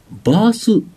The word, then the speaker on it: BaaS: で、もう一つですね、バース提携事業、 BAAS と書いて、バースと。